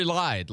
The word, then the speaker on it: Lied: He's a redshirt freshman, and it's actually a Curry lied. Like, he lied. That's the guy's name.